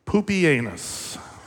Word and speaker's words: Pupienus: This was Emperor, actually, okay, so I'm going to only say his name once, but I promise you this is how his name is pronounced. You can Google it. Emperor Pupienus. Yes, okay.